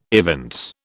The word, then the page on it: evince: British English